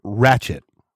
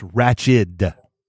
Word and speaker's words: ratchet: It's not ratchet. It's ratched. D.